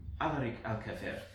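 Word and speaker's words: Alarik Al-Kafer: As Rain goes and he says, friends, I would like to introduce to you Alaric Alcafer, one of the merchant lords of Comis.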